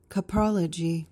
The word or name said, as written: coprology